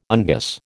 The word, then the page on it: unguis: American English